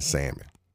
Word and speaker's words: salmon: Next thing is, a lot of those same types of people, the Bugettos, right, they will pronounce the L in salmon.